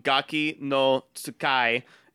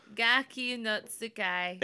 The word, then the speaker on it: Gaki no Tsukai: Well, it's spelled phonetically. – Yeah, I mean, if you know Japanese, it's T. You know, it's TSUKAI. – Gaki no Tsukai.